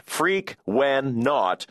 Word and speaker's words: Frequenaut: It's pronounced Freak-wen-naut, and it's based on the word frequency. The naut part is the same as an astronaut, even though I don't really know what naut means.